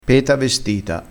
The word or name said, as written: peta vestita